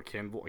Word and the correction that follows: Cavill: Cavill. It's Henry Cavill. – Cavill.